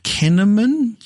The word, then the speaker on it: Kinnaman: Joel Kinnaman? Oh, walk of shame on that one. The main actor from that, the one who was in... – It's pronounced cinnamon.